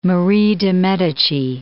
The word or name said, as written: Marie de Medicis